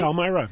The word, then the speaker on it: Palmyra: Palmyra, is that right? – Palmyra. Mm-hmm.